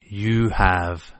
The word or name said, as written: you have